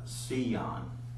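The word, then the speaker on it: Sion (ציון): And the word for Zion there is pronounced Sion. It's S-E-E-Y-O-N-E. You know, that's the way it's actually pronounced. And spell it again, C-E-E? – Yeah, it's actually on there.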